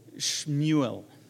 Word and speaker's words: Shmuel: Or if you want the Hebrew version, Shmuel. It's S-H-M-U-E-L, Shmuel.